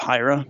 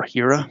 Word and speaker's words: Hiera: And then there's one other main component called Hira, or Hira. You'll hear it pronounced differently, H-I-E-R-A, so however you prefer.